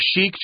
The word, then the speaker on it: chic: Yeah, and the reason it's pronounced so crazy, it looks like you need to say chic, right? – Yep. – But it's a French word, which just means fashionable and really elegant.